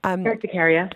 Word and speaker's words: urticaria: A message here saying, my wife suffers from dermatitis or urticaria. Did I say that right? – Urticaria.